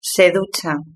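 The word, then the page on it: se ducha: Spanish (Spain)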